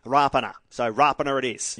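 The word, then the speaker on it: Rapana: His mum was into us for years saying it's Rapiner.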